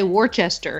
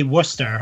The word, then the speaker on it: Worcester: Every time I write your address, I say Worcester. – It's Worcester. Yeah, people say Worcester, but around here we say Worcester.